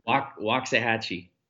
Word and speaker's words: Waxahachie: Yeah, Waxahachie.